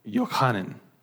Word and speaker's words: Yochanan: And in the Hebrew, that's pronounced Yochanan. And what it means is, the Lord has been gracious.